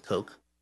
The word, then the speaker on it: Koch: Like Cox, a little wary about Koch. – Oh. It's pronounced Coke.